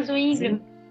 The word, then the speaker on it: Zwingli: É, Urico Zinglo. – Ah, Zwingli.